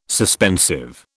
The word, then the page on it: suspensive: British English